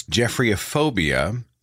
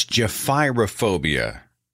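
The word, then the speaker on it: gephyrophobia: And I was talking about the fear of bridges, and I had mispronounced the term for the fear of bridges. I had simply read it. I got the phonetic wrong. I said it is Jephryophobia. It's Jephyrophobia. Jephyrophobia, Jephyrophobia.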